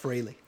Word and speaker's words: Frehley: It's pronounced Frehley. – I don't give a shit. – Oh, I would say Frehley too.